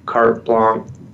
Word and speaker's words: carte blanche: architect or designer, don't give them carte blanche approval. Is that how you pronounce that? Yeah. Right yeah.